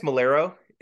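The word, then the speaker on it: Maiellaro: And he then tells me, I just sent this picture to Dave Willis, Matt Malario, is that how you say his name, Matt? – I think it's Malero.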